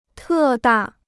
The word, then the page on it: 特大: tè dà